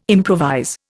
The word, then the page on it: improvise: Indian